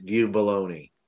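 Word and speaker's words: Vieux-Boulogne: According to the pronunciation that I found on the internet, it is in fact view baloney.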